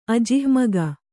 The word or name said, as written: ಅಜಿಹ್ಮಗ